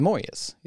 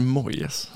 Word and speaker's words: Moyes: Att man gjorde det katastrofala misstaget att plocka in David Moyes istället för att tänka på. Varför uttalar du Moyes? – Jag säger alltid Moyes. Jag tycker okay. Moyes låter så jävla konstigt.